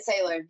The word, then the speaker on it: Sailor: So Brit Sailor, yeah, you got it.